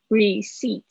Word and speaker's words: receipt: Okay，接下来是 receipt，receipt 这个呢很特别啊，receipt 这个是发 re re receipt receipt p 不发音哦，